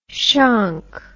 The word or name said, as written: shonk